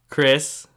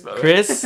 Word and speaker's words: Chris: At Chris, spelled with a C-H, not a K. Chris and my last name, Luxon. – So Chris Luxon, C-H-R-I-S-L-U-C-Z-O-N.